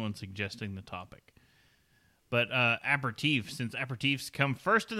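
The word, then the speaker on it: apéritif: It is pronounced aperitif in French and aperitivo in Italy.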